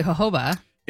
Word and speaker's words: jojoba: It has rosemary, citronella, tea tree, geranium, jojoba, and chamomile. – I like the way you emphasize jojoba. You said it correctly, jojoba.